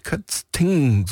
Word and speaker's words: Kötzing: Kötzing, so muss ich es richtig aussprechen.